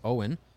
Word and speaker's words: Owyn: Another deal here that we will announce in 1 second after I tell you guys about Owen. Owen is spelled O-W-Y-N, stands for only what you need.